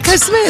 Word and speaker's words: Koh Samui: Hang on, is it Koh Samui or Koh Samui? – Yeah, Koh Samui is what I said. – Oh, Koh Samui.